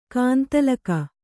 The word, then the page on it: ಗೊಂದಲಿಗ: Kannada